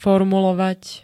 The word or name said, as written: formulovať